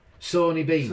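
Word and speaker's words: Sawney Bean: Scottish cannibal, Seeny Bean. Right? Sawny Bean. – Sony Bean, Right.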